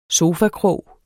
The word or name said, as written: sofakrog